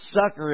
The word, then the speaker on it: succor: That is, this word looks like succor, but it's actually pronounced sucker if you put it on your English speaking dictionary.